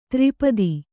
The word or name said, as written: ತ್ರಿಪದಿ